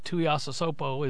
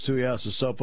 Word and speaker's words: Tuiasosopo: So when you see a word, you just pronounce it as it's written, syllable by syllable. That's the secret. – Okay. – I learned that. I don't know where. But Tuiasosopo is, how you say it. And you got it. Nailed it.